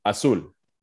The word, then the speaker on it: Azul: Is it Azul? Azul? – Azul. – Azul. Okay. – Yeah, there you go. That's perfect.